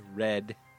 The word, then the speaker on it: redd: A redd. Ah, she doesn't know anything. – A redd with two D's. You gotta pronounce it like red.